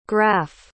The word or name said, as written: graff